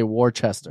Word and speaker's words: Worcester: So you pronounced it correctly. – Did yeah. – I? – Yeah, a lot of people say Worcester.